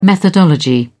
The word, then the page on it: methodology: British English